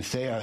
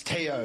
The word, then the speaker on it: Theo: I had a teammate named Theo, but over there they don't pronounce the H, so it's T-O. And I remember I was screaming him, Theo, Theo, Theo, and he's just not looking at me.